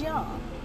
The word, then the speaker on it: Jung: It's spelled J-U-N-G. Some people pronounce it as Jung. Some people pronounce it as Young.